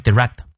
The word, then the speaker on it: direct: 当然也可以念 direct，英式发音念 direct，美式发音 direct。 proportion，注意它的音节。 In direct proportion to one's distance，重音在前，from the problem。好，从头到尾我们来过两遍。 Idealism increases in direct proportion to one's distance from the problem。